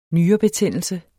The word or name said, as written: nyrebetændelse